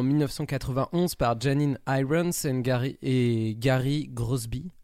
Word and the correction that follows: Crosby: Crosby, pardon. Crosby, je ne sais pas la prononciation. Je dirais Crosby. ouais, Crosby. Avec, un, avec un Y.